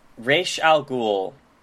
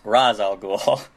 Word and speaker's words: Ra's al Ghul: Okay, so Raish Al Ghul, which in the movie it's pronounced Raz Al Ghul. But that's not that's not how you fucking say it.